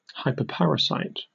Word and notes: hyperparasite: British English

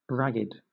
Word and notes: ragged: British English

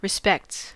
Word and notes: respects: American English

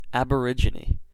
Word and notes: aborigine: American English

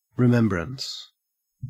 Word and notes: remembrance: Australian English

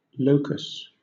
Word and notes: locus: British English

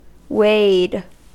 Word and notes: wade: American English

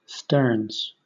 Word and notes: sterns: British English

